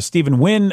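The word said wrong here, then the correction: Steven Yeun: Did I say his name right? – Stephen Yoon. – Stephen Yoon. Thank you.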